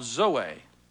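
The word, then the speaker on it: zoe (ζωή): That word life is a word in the Greek called zoe. Zoe. Z-O-E. Long O with a little two dots over the E. Zoe.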